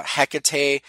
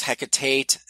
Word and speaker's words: Hecate: I'm pretty sure how that's pronounced, Hecate. I've heard it pronounced Hecatate, though I'm not sure where they would get that second T from.